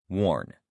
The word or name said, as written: warn